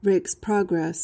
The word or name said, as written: rake's progress